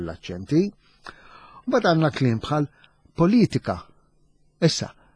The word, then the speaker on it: politika: Allura qiegħda t-tielet sillaba mill-aħħar politika, politika. Dak huwa aċċent aħna ngħidu l-użdruċċjolu għax meħud mit-Taljan.